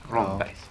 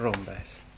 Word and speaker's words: Rombergs: Uttalas det med N eller M i mitten? – De säger nog helt med M. – Med M, som är Magnus. Rombergs. – Ja, Rombergs.